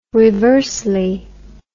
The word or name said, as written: reversely